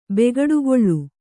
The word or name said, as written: ಬೇಗಡೆಗಳೆ